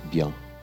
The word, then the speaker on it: bien: Por exemplo, é, quando você vai falar uma palavra é, simples em francês, é a palavra bien. Ou, em francês da França, a pronúncia seria bien.